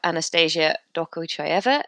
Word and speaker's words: Anastasia Dokuchaeva: First up, we have Anastasia Dokochayeva, Head of Partnerships at ClauseMatch.